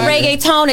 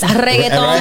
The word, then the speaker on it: reggaeton: He said reggaeton. It's reggaeton.